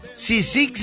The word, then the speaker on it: Cysic: Sisic se escribe C-Y-S-I-C.